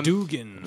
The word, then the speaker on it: Duggan: Brian Posehn and Jerry Duggan. Or Dugan, I'm not sure how you say it. – Dugan.